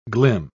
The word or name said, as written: glim